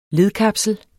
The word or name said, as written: ledkapsel